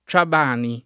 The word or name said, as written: Ciabani